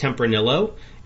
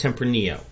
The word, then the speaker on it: Tempranillo: I think it's okay to pull them aside and say, hey, honey, so it's not pronounced Tempranillo. It's the wine is pronounced Tempranillo.